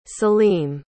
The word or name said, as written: salim